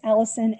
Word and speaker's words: Allyson: It's Allyson A L L Y S O N, and of course Park.